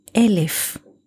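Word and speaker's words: Elif (אלף): 1,000 years, or the number 1,000, we say in Hebrew, Elif. Elif, Aleph, Lamed, Fesufit. The same letters with a different pronunciation gives us the word Aleph.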